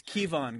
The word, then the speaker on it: Kevon: If they would have hired one of those guys, that's the Kevin Looney. – No, it's Kevon, guys. He spells it K-E-V-O-N.